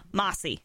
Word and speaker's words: Mossy: Well, it's understandable because the way I say my name, you know, Mossy. – Mossy. – It could sound like Massey.